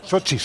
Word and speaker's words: Sochi: Sochi, Sochi. – Sochi. Entonces ya más fastidio la rima de Rocky en Sochi. – Sí. – Rima igual, ¿eh? Es asolante, pero... – Rochi en Sochi, ya está.